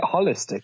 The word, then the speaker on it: holistic: Isn't it holistic? No, Hol- holistic, holistic. Ho- ho- as in the whole holistic.